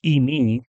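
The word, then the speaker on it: eimi (εἰμί): What's interesting, I'm not going to spend a lot of time on this, but the word was is a verb, and it's eimi, that's how it's pronounced in Greek.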